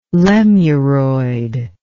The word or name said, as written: lemuroid